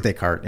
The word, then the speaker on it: Descartes: It's French. So there's all these extra letters. Mm-hmm. It's Descartes. You know, like, in fact, I think the T might even be a little bit silent, like Descartes.